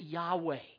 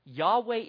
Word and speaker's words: Yahweh: But we pronounce it Yahweh. All right, Yahweh is the term that God revealed to Moses.